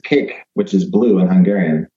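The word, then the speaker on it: kék: It's pronounced like a piece of cake, cake, mm-hmm. but it's actually K-E with an accent, K, and it's pronounced cake.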